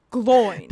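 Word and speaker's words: Glóin: So the emphasis is on the O. Oin. Gloin.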